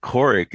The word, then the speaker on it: Kourik: But Korick is spelled K-O-U-R-I-K.